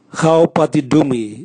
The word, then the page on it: gao bwatidumwi: Raga